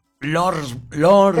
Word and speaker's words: Lordsburg: Se escribe L, Lord, y lo S, Berg, Lordsburg. – Lordsburg. – Pero para uno... Lordsburg, sí, exacto, New Mexico.